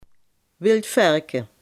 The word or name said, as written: everzwijn